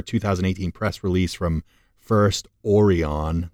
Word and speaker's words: Orion: Yeah, Orion, like the star cluster.